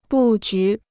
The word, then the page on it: 布局: bù jú